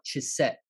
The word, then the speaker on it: Chesed: It's called, is this particular word called Chesed.